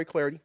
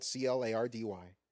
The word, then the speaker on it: Clardy: I'm on Twitter at Troy Clardy. Last name is spelled C L A R D Y.